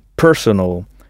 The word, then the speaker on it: personal: Il faut bien frapper la première syllabe, personal.